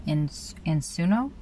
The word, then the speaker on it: ensino: Escola Ensino? – Ensino. – And how do you spell that? – E-N-S-I-N-O. – E-N-S-I-N-O. E-N-S-I-N-O? – Yeah.